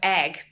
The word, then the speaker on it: Eigg: And then when I was six, we moved off to the Isle of Egg, pronounced like egg. – An egg. Yeah, E-I-G-G, like an egg.